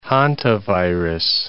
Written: hantavirus

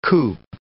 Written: coop

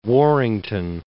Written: Warrington

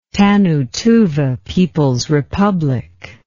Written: Tannu Tuva People's Republic

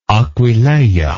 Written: Aquileia

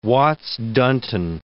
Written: Watts-Dunton

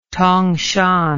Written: tongshan

